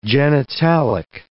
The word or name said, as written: genitalic